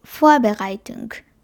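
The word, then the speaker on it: Vorbereitung: ドライ、フォアベライトンク。これは一番初めにアクセントがあって、準備っていう意味です。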